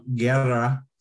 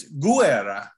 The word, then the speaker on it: Guerra: In California, the Spanish pronunciation of Guerra, that's how the Spanish would pronounce it, which is fine, but it happens to be Italian. And in Italy, it's Guerra. Each vowel gets pronounced in Italian, whereas blended in Spanish.